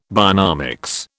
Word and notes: bionomics: British English

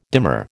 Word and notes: dimmer: American English

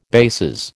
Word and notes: bases: American English

